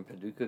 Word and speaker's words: Paducah: Paducah? – Yeah, we're in Paducah, Kentucky.